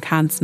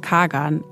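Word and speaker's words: Kağan: Philipp nennt ihn zum Beispiel Kahan, manche sagen auch Kagan, also so wie man die türkische Schreibweise auf Deutsch aussprechen würde. Er selbst spricht seinen Namen aber Kahn aus, deshalb nennen wir ihn hier auch so.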